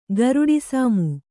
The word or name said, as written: ಗರುಡಿಸಾಮು